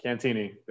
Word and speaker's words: Cantieni: Cantieni.